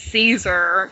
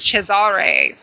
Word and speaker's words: Cesare: But, like, I just found out recently that another friend of mine, actually, the whole time, she insisted that the correct pronunciation of the original Captain of Caligari was Cesare as well.